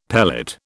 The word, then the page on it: pellet: British English